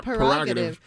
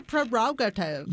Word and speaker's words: prerogative: It's my not prerogative. It's my prerogative. – You spell it P-R-E. – It's dumb. – Never heard of that or knew that in my life. I would have got that all wrong. – And not only P-R-E, P-R-E-R.